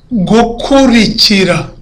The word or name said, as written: gukurikira